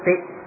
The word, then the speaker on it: te: Mamatma, syata, mamatma, asya atma, tava, te, te, it is called te. Because of sandhi rule, takara is there. You have to, when you are splitting before atma, that it has to be pronounced as te.